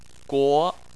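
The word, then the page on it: guo: tone 2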